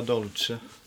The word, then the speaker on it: Dolce: D-O-L-C-E. – Ja, det... La Dolce Vita. – Uh-huh. – La Dolce. Jag tror det uttalas Dolce. – Ja, det är det.